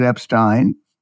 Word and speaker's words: Epstein: And of course, he pronounces it Epstein, Alex Epstein.